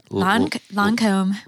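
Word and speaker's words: Lancôme: Lancome.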